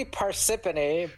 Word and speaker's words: Parsippany: How do you pronounce that? – I think it's technically Parsippany, but I know it's a weird city name.